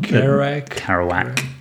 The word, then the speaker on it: Kerouac: Kerouac. – It's a combination of the names of two characters from a Jack Kerouac journal that the founder spotted when there was a Jack Kerouac exhibition at the New York Public Library.